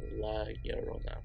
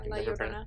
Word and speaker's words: La Llorona: Like I can never pronounce her name right. It's La Llorona. – Yeah, La Llorona.